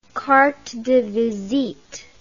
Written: carte de visite

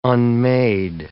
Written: unmade

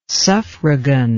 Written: suffragan

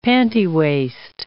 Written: pantywaist

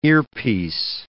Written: earpiece

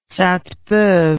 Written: saintebeuve

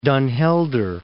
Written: Den Helder

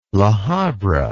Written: La Habra

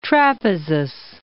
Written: Trapezus

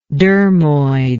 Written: dermoid

